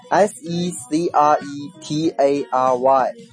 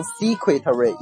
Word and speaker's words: secretary: 还有的后缀 ary 表示从事什么什么的人，比如说 secretary S E C R E T A R Y，这个词啊一定不要读成 secretary，因为我们都知道 secret 是秘密的意思，不要顺着读下来啊，它叫 secretary，叫做秘书，帮你保守秘密的人就是秘书。